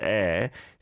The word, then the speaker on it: 의: 의, which is pronounced air, just means like apostrophe S.